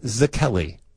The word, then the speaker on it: Szekely: We're not Louis competing Zakelli. Against each other, right? We're just playing. – Wow. His name is Hungarian. It's spelled S-Z-E. K-E-L-Y. Who is Elizabeth Woolridge Grant?